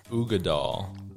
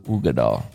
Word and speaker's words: Uigeadail: It's an Ardbeg Scotch, and it's Ugadal. – Ugadal. – But it's spelled UIGEADAIL.